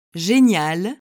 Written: genial

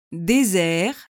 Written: desert

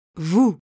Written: vous

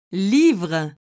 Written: livre

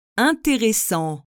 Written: interessant

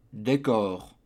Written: decor

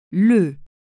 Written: le

